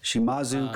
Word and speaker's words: Shimazu: Akira was the daughter, and the dad was Shimuza? That's probably butchered. – Shimazu Koji, yeah. – There you go. – I'm just reading it. – That sounds right to me.